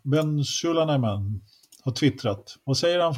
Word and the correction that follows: Sulayem: Mohammed Ben Sulayem.